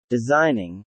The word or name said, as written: designing